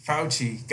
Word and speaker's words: Fauci: Dr. Fashush. I fucking murdered this guy's name. How would you say that? Well, Dr. Fauci. Fauci.